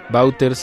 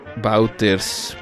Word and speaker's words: Wauters: Juan Bauters se escribe W-A-U-T-E-R-S. Bauters.